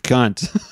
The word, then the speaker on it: Kant: Yeah, he said, this is the German way. The other way is Americanized. – Okay. – This is not how you pronounce his name. You pronounce it Kant.